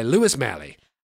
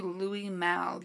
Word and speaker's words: Louis Malle: Oh, it's actually Louis Malle. You're mispronouncing it. – Are you sure? Oh, because look at this. That reads, if you look there, that reads Louis Malle to me. How How would you pronounce this? – I'm pretty sure it's Louis Malle. He's an acclaimed French filmmaker, actually.